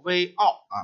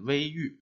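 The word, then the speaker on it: 隈隩: 注解给我们说的这两个字叫微奥啊，实际上这个郁字是个多音字，既可以念奥，也可以念郁。但是在这个句子里头，最好是取“ 玉” 的读音啊，念“ 微玉”，因为这个“ 玉” 本身就指的是水的岸边或者是山沟里头那些曲折的地方。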